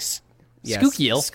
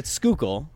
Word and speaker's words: Schuylkill: I grew up in Schuylkill. You said like Schuylkill. – You were like Schuylkill. And it's Schuylkill.